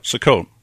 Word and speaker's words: Sukkot: Sukkot.